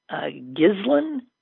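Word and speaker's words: Ghislaine: Okay, so I don't know how to pronounce her name. Gislin? Somebody tells me it's not a hard G.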